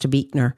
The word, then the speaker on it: Buechner: It's Buechner, but it's pronounced Buechner.